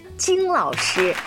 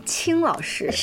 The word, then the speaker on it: 金: 今天直播间为大家请来了金瑜伽的教练金老师。 – 大家好，我是金老师，草字头下面一个青草的青，是不是很多人会把这个字儿念成青老师？ – 是的，是的，经常有人读错，因为我姓王嘛，叫王金，然后我们的瑜伽馆叫金瑜伽，所以很多朋友都很亲切的就直接喊我是金老师。